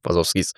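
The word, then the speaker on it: Wachowskis: Ist dann auch, glaube ich, sogar mehrfach genannt worden von den Wazowskis. Habe ich jetzt richtig gesagt? – Ja. – Doch, Wazowskis.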